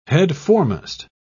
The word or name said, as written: headforemost